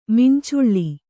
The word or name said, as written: ಮಂಚಲು